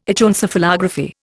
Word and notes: Echoencephalography: Indian

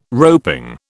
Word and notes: roping: British English

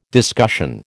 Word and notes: discussion: American English

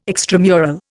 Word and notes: extramural: Indian

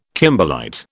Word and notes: kimberlite: British English

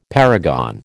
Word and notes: paragon: American English

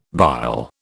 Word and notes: bile: British English

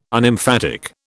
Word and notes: Unemphatic: British English

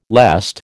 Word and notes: lest: American English

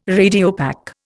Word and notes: Radiopaque: Indian

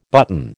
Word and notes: button: American English

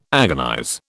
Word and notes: agonize: British English

